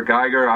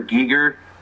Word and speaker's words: Giger: Yeah, I just watched that a couple weeks ago, and I found out that all of us have been saying that wrong, right? H.R. Geiger, I always thought, and then they said H.R. Giger throughout the whole film, and I was like, wow, yeah.